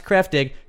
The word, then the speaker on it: Kraftig: It's spelled K-R-A-F-T-I-G, pronounced Kreftig.